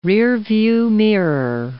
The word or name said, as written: rearview mirror